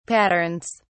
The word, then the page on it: patterns: British English